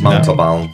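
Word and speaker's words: Montalbán: Montalban.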